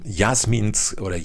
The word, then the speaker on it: Jasemin: So, jetzt bist du aber seit 2018, bist du die Autorin eines eigenen Buches und du hast mit Jasemins Kiosk, wie spricht man es richtig? – Ich spreche Jasemin aus.